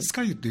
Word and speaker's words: 酸ヶ湯: 酸性の酸って書いてすって読むんですけども、ええ、スカユ